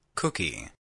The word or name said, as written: cookie